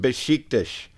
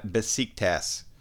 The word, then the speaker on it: Beşiktaş: And when you find a team called Beşiktaş, it's pronounced Beşiktaş, not Besiktas.